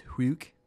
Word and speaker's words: sjuk: So, in Swedish, there's a sound that is spelt S-J, and it's pronounced hu, so like sort of like H-W-O sort of thing, like hu. So like S-J-U-K is huk, and it's kind of it sounds like the wind.